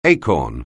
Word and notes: acorn: anglais

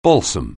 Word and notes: balsam: anglais

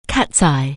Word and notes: Catseye®: anglais

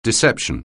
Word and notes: deception: anglais

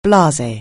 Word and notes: blare: anglais

